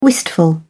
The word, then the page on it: wistful: British English